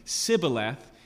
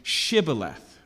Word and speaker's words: Shibboleth: And so the difference we see here is the difference between Sibboleth and Shibboleth. Okay, so the SH noise is the difference.